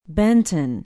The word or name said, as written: benton